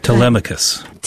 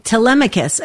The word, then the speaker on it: Telemachus: But here's the Telemachus. – Telemachus.